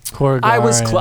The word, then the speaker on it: Korugarian: But it's K-O-R-U-G-A-U-N, right? – It's Corinthian. – No. – Corinthian. – That's Greek. What is it? Korugarian. – Korugarian. – I spelled it. – There's an extra G in there. – I was closer. I had more syllables. – K-O-R-U-G-A-R-I-A-N.